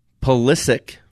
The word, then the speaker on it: Pulisic: Moving on to soccer news, Christian Pulisic, yeah, that's how you say it, had two second-half goals.